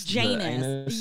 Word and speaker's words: Janus: Like Janice, or like Janus? – Janus, yes. It's spelled anus with a J.